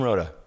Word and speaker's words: Rota: Jim Rota, yes.